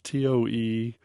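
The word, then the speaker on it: Tonéx: In fact, his first album said it's pronounced Tone, TOE/NAY. Tone,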